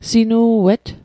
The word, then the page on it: sinu'et: Mi'gmaq